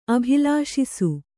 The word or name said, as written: ಅಭಿಲಾಷಿಸು